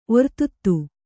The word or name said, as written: ಒರ್ತುತ್ತು